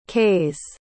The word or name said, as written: qays